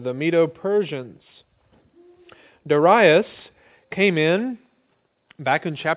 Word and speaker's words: Darius: The first year of Darius, or you'll sometimes hear that pronounced Darius, the Mede.